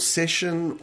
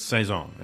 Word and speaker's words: Saison: Saison or session or... – It's Saison. That's all right. How you pronounce it. Saison, S-A-I-S-S-O-N.